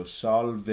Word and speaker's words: salve: The ending, you want to avoid diphthongs as usual, so salve, you want to avoid that and just pronounce it as salve.